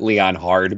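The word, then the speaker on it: Leonhard: By the way, anybody that's listening to this podcast for the first time, we joke and pronounce it Leon Hard because that's how it's pronounced anyways.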